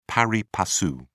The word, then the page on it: pari passu: British English